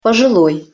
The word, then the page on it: poziloj: Russian